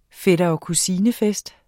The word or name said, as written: fætter